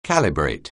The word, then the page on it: calibrate: anglais